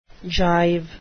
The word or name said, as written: jive